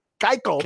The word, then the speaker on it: Keuchel: Dallas Keuchel. – Keuchel.